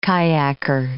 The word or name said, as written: kayaker